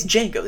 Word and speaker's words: Django: Well, it's Django. The D is silent.